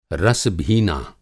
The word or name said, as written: रस-भीना